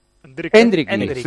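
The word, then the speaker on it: Endrick: La pronunciación es como con acento en la E. – Endriki.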